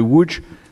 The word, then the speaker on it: Łódź: Alors, vous, vous disiez que vous étiez évidemment à l'école de, cinéma de Woods, on dit Woods, je crois. Oui, Łódź. Vous dites Lodz pour qu'on comprenne. – Oui, non mais en, français, on, le dit Lodz. En fait, Lodz, ça euh, s'écrit avec un, O qui a un accent, et ça, ça fait OU en polonais.